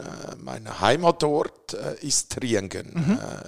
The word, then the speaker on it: Triengen: Trienge, ist das richtig? Spricht man das richtig? – Äh, ist Triengen, das ganz falsch? Triengen, ja. aber äh, das stimmt nicht ganz.